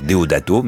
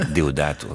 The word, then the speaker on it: Deodato: J'allais dire Deodato, mais Deodato.